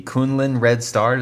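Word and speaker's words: Kunlun Red Star: Fired as coach of the Kunlin Red Star.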